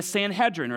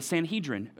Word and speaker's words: Sanhedrin: which would be the Sanhedrin or the Sanhedrin. You can pronounce it either way.